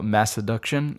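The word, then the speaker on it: Masseduction: I don't think it's pronounced mess. I think it's called Mass Seduction.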